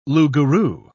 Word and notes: loup-garou: British English

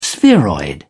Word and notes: spheroid: British English

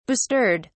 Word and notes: bestirred: British English

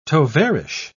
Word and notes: tovarich: British English

